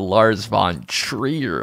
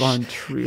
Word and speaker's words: Lars von Trier: Lars von Trier? – Yes, he's a Swedish filmmaker, and his name sort of rhymes with front Trier, which is a portmanteau of frontier and tree.